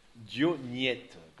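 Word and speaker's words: Diognète: Dioniette, comme ça se prononce, avec un accent grave.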